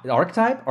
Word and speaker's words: archetype: Archetype? Archetype. – I think it's a hard C.